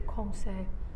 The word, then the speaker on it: conseil: How to say it? – Conseil.